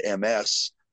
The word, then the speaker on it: Ms.: So somebody invented M-S with a period, pronounced Ms., and means it's none of your business that whether or not I'm married.